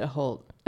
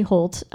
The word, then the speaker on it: Hoult: And once again, Holt, H-O-U-L-T. – Mm-hmm. – How do you say that? How would you say it? – I said it Holt. – Okay, let's say Holt.